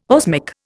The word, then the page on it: osmic: Indian